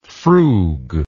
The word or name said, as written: frug